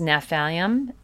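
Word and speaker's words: Gnaphalium: Another really good remedy for helping with different pains and is Naphalium, and that is sciatica pain, and that's spelled G-N-A-P-H, okay?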